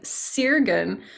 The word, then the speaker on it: Sirgun: And then I get the name Sirgun, which I actually pronounce that way because that's how it's spelled, but it should actually have been spelled Sargun, and it should be pronounced Sargun, which means with the qualities, like the gunas, you know, with like the earthly qualities.